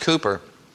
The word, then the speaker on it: Cowper: Looks like Cowper, but it's pronounced Cooper.